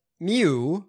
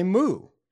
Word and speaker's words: Mu: The Brits pronounce it like mew. – Mew. – While Americans say moo. – Oh, okay. Okay. So what are we? We're gonna get the Mu variant.